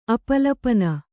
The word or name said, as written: ಅಪಲಪನ